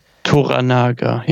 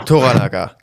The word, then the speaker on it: Toranaga: Wir begleiten aber diesen Lord Toro Naga. – Toranaga, ja. – Toranaga, genau. So ist es ausgesprochen, richtig.